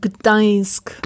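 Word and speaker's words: Gdańsk: Gdansk. Gdansk.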